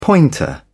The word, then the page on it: pointer: British English